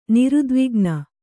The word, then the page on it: ನಿರುದ್ವಿಗ್ನೆ: nirudvigne